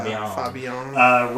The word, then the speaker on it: Fabienne: I didn't even catch this when I first saw it, but so the last scene in the movie is Butch and Fabienne. Is that how you pronounce it? – Yeah, Fabienne.